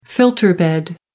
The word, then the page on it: filter bed: American English